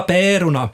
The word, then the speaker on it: pärorna: Det ska vara pärorna, och här ska alltså ä uttalas smalt, mittemellan ä och e. Pärorna, är det där mellan e och ä? – Pärorna, inte vet jag.